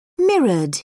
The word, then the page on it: mirrored: British English